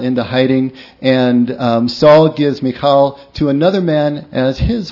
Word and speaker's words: Michal: Michal is her name. Sometimes pronounced Michael in English. Michal.